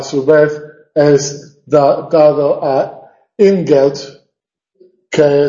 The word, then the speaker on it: Ingeld: Bueno, se casa con Ingeld, deletreado con D, pero pronunciado con T,